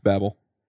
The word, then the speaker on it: Babel: I've met two of the maintainers. It's Babel.